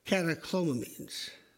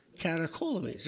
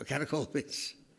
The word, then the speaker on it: catecholamines: It's catecholamines. No, catecholamines. There we go, catecholamines.